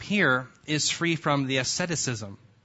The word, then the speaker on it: asceticism: I know how to say it then. I was worried I don't know how to say it at all. Asceticism. Oh, good. Did I say it right? Good.